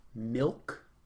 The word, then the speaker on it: milk: Milk. – That is correct. That is how you say an I and not an E. – Milk. – Because there are no E's in milk.